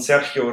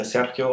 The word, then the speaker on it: Sergio: Ja, und Sergio Ramos, spricht sich ja Sergio aus, nicht Sergio, habe ich inzwischen auch gelernt.